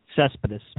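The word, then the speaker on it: Céspedes: I keep saying Cespedes. I should say his name. – Cespedes.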